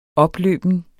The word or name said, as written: opløben